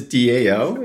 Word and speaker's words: DAO: I got it wrong already. A DAO. Okay. Keep educating. – Yeah, no worries. It is DAO. It's definitely how it's spelled.